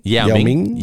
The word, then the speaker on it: Yao Ming: Yao Ming.